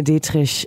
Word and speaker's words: Dietrich: And I feel like Marlene Dietrich is obviously an icon.